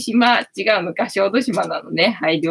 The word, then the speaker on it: 小豆島: 小豆島って読むのか。あずき島じゃなくて、小豆島って読むのね。あずき島だと思ってたよ。